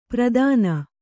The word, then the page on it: ಪ್ರದಾನ: Kannada